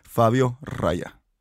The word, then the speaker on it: Fabio Raya: Well, actually, I don't know if he pronounces that R as strong as I do, but Fabio Raya.